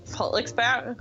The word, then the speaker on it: Frederiksberg: So for example, there's a part of close to Copenhagen, an adjacent city called Frederiksberg, and it's pronounced Frederiksberg. So you can sort of hear in your voice, like you're catching yourself as you're pronouncing it.